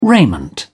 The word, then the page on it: raiment: British English